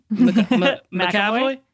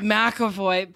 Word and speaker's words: McAvoy: On Instagram Live, someone asked James McAvoy. Is that how you say it? – McAvoy? – Oh, God. God, McAvoy.